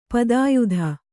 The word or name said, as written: ಪದಯಿದ